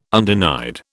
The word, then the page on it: undenied: British English